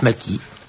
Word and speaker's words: Maschi: M-A-S-C-H-I, mais il se prononce Macky.